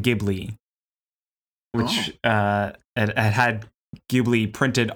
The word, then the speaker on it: Ghibli: But the way to pronounce it for the studio is Ghibli with a J sound.